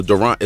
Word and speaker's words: Durant: It's actually, honestly, my name is French, so Durant is actually the correct way.